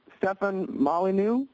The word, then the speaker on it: Stefan Molyneux: All right, let's talk with Stefan Molyneux.